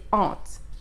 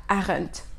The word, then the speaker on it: aren't: La prononciation c'est aren't. et non pas aren't.